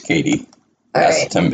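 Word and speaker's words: Katie Aselton: I am on air looking up how you pronounce Katie Asselton, because I don't want us to go back and forth, you know.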